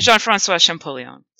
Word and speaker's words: Jean-François Champollion: And then there's a French scholar called, if I'm getting the pronunciation right, maybe you want to do the pronunciation. – Jean-François Champollion.